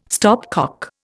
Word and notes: stopcock: Indian

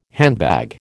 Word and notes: handbag: American English

